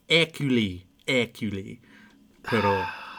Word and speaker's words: Hercule Poirot: Kenneth Branagh taught me how to say it in the new movie. Hercule Poirot.